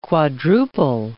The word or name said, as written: quadruple